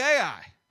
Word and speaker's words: Ai: It's spelled Ai. So I just say Ai.